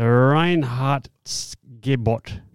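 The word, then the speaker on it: Reinheitsgebot: Look at the label at the back, and there's a purity law of 1516, or it's called the Reinhardtsgebot or something. – Yeah, that would have been perfect pronunciation. – That would have been spot on. Yeah. – The Reinhardtsgebot.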